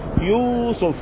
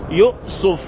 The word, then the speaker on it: يوسف: يوسف، فان يوسف بتنطق الكلمة بتطرق، يوسف يوسف يوسف يؤسف يؤسف يؤسف. ايه رأيك بقى؟ ست الطرق بتتنطق بيها اسمه، واخد بالك من كلمة اسف؟